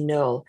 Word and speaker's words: Knerl: And our guest today is Lindsay Noel. Welcome, Lindsay. – Thank you for having me. – And just right up front, let me tell you that Lindsay's last name is spelled K-N-E-R-L. It's a silent K.